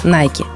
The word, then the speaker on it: Nike: Правильно говорить Nike.